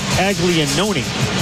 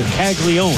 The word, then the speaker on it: Caglianone: It's spelled C-A-G-L-I-A-N-O-N-E, like Caglianoni, but it's just Caglione.